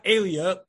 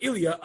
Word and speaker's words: Ilya: Ilia, not Alia, A-E-L-I-A, but Ilia, I-L-Y-A, is the Islamic or the Arabic pronunciation of the Roman Alia, meaning Jerusalem.